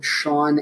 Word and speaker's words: Sean: My name is spelled Sean, S-E-A-N, Covey, C-O-V-I.